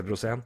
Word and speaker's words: Rosén: Eh, Kalle Rosén, jeg tipper det er det, sånn- som, det som sånn uttales, sjøl om ikke det er en aksent her nå. I, det er sånn TSN skriver det, men det heter, han heter Rosén.